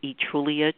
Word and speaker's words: Etrulia: And if you type in my name, which is Utrulia Troy Lee, because that's much shorter than the book title, and Utrulia is spelled E-T-R-U-L-I-A.